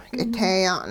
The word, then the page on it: k'iteeya'n: hupa